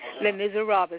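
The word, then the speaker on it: Les Misérables: Les Miserables is how you say it. Can you say it? – Miss Miserables. – Say Les Miserables. – Les Miserables. – Okay, that's close enough.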